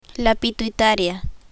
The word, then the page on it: pituitaria: spanish